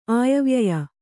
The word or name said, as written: ಆಯವ್ಯಯ